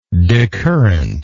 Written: decurrently

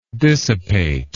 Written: dissipator

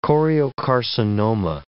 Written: choriocarcinoma

